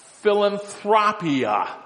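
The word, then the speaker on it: philanthropia (φιλανθρωπία): It's interesting that the little word when it says, and His love for mankind appeared, that's the Greek word, philanthropia. That's the Greek word. That's how you say it.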